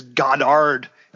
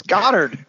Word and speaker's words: Goddard: When she goes around saying Goddard, I'm like, nobody says Goddard. It's Goddard. It's the only way to pronounce it.